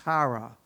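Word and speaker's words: chara (χαρά): So the Greek word for joy is a word, it's pronounced chara, and it appears 57 times in the New Testament.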